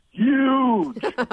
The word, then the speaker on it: huge: Well, Kathleen, first off, I'm going to correct your pronunciation of the word huge. It's huge. – Okay. – With a Y.